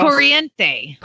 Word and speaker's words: Corriente: The name of the ca- the breed of the cow is probably Corintini. I don't know how to say it. It's a Longhorn, uh, and this is in Illinois. I have a Longhorn cross Corriente. – Corriente, Longhorn cross steer.